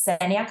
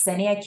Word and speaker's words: Ksenia: So my name is Senia Castanenka, that's Senia K is silent, and I'm an assistant professor here at Massachusetts General Hospital and Harvard Medical School.